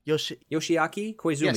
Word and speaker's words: Yoshiaki Koizumi: Yoshiaki Koizumi.